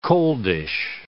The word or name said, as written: coldish